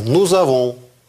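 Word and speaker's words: nous avons: «Ну», и раз здесь начинается с гласной, то «зэ» читается «нузавон». «Нузавон» – «мы имеем». «Вузаве» – «ильзон» – «эльзон».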